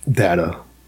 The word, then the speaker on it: Data: Not a child's voice, but a more like almost like a Data from Star Trek. That would have been more Data. – Whatever. – Dat-A. Pronounced Dat-A. – D-A-T-A. – D-A-T-A.